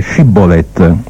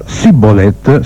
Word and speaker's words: Shibboleth: E se quello rispondeva di no, gli dicevano ebbene prova a dire Shibboleth, con lo Shibboleth, e se quello diceva Sibboleth senza badare alla pronuncia, lo prendevano e lo scannavano presso i guadi del Giordano.